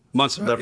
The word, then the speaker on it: Montserrat: Were the little frogs in Montserrat? Do I say it right? Mm-hmm. Montserrat.